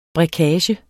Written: brækage

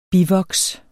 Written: bivoks

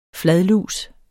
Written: fladlus

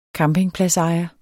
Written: campingpladsejer

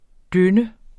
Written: døn

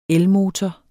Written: elmotor

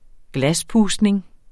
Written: glaspustning

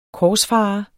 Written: korsfarer

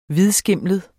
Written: hvidskimlet

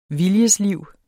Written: viljesliv

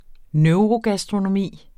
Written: neurogastronomi

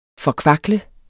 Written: forkvakle

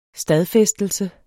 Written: stadfæstelse

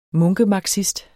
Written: munkemarxist

